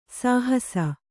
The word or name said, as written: ಸಹಸ